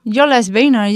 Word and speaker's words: Jólasveinar: So in Iceland, I don't know how to pronounce this in Norwegian, I think. Okay, it's like Jólasveinar. – Jólasveinar, yes.